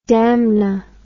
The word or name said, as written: damna